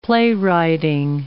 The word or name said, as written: playwriting